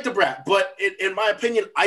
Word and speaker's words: Da Brat: I think, you got to say it right. It's Da Brat, not The Brat, like some white people would say.